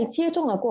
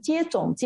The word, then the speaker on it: 接种: 这个男童在接种了过后呢，得了一场小小的病，不过他却免疫了。所以有时候这个“ 种” 字哈，我知道很多人念成“ 接种”，接种其实不是“ 种” 啊，不是种子的“ 种”。